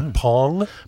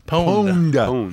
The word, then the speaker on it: pwned: Did you say Pong? – Pwned. – How'd you spell that? – P-W-N-E-D. – Thank you.